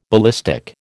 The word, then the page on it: ballistic: American English